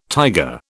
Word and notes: tiger: British English

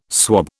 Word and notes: swob: British English

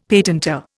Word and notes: patentor: Indian

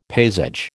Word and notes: paysage: American English

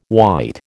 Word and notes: white: American English

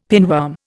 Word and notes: pinworm: Indian

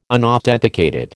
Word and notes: unauthenticated: American English

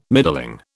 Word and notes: middling: British English

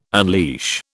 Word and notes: unleash: British English

